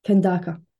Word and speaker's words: Kandaka: Have I said that right? Kandaka? – Kandaka.